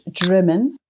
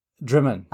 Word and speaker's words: Drymen: Yeah, it's pronounced Drymen. – Drymen.